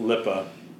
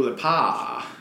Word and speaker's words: Lipa: No, well, shit, and it's also not pronounced Lippa. – Dua Lipa.